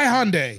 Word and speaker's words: Hyundai: Brought to you by Hyundai. Pronounced like Monday.